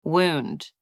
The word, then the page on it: wound: anglais